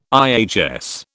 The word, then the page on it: IHS: British English